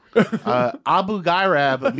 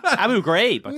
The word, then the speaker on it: Abu Ghraib: How is it pronounced? – Abu Ghraib. – Is that it? – Is that really the one on the paper yeah. – right now? – Yeah. Abu Ghraib.